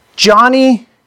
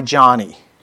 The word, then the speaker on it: Joni: Joni Erickson Tada. Some of you have pronounced her name Joni. I understand she likes to go by Johnny.